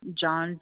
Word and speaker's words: John: John, JOHN.